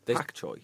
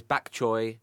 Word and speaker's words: bok choy: Is it pronounced pok choy? Pak choy. – There's bak choy, pak choy. – Oh, really? – I think it's only an anglicised interpretation of the sound.